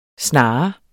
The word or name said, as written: SNAREN